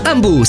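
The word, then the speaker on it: Hambu: Ambu si scrive con l'H.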